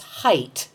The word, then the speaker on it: height: I hear lots of people pronounce height as though it ended with TH, as in length and width.